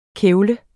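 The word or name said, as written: KÆVLEDES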